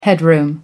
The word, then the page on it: headroom: American English